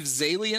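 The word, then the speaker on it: Zaillian: Steve Zalian, am I saying his name right? – Yeah.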